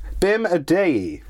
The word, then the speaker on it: Bim Adeyi: Bim Adeyi.